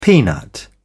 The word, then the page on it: peanut: British English